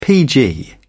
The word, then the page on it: pg: British English